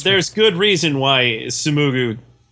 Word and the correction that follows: Sumugi: I said it wrong. Yeah. There's good reason why Sumugi is against clothing.